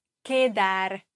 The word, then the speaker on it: quedar: Sich treffen, die Grundform, heißt quedar. Quedar, Q-U, wird als K ausgesprochen, also kein U, man hört kein U.